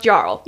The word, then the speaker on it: jarl: So, okay, it's not just me, but it's pronounced Jarl. – Yeah, Jarl. – Okay, good to know, because the whole time I'm like, Jarl.